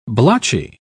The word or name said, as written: blotchy